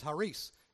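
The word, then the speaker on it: charis (χάρις): In the original language, in the Greek, in the original language, that word is charis, spelled C-H-A. I guess it's not spelled that. In English it's spelled that way, but it's charis.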